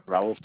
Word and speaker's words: Raul: Raul Feliciano. – Can you spell that for me? – R-A-U-L. Last name is spelled F-E-L-I-C-I-A-N-O.